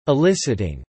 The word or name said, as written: eliciting